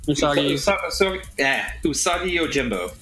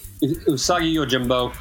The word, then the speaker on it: Usagi Yojimbo: Am I saying that right? Usagi. – Usagi Yojimbo. – Usagi Yojimbo.